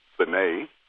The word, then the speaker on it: Funnye: And so being ostracized, after all, my name is Fanay, so it's spelled F-U-N-N-Y-E. So that then has also led to people pronouncing my name 17,000 different ways.